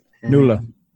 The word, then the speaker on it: Nuala: Nuala.